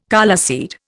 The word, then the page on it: coleseed: Indian